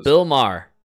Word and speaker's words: Maher: Bill Maher.